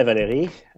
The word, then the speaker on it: Valérie: Hi, Valérie. I'm very good. Very good. Thank you. – And, I love hearing my name pronounced the French way.